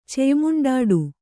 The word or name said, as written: ಚೆಯ್ಮುಂಡಾಡು